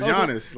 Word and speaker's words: Giannis: Yeah, his name is Giannis. Like, you don't pronounce the G.